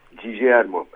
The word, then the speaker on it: Guillermo: Guillermo diye okunuyor galiba. – Nasıl? – Guillermo, evet.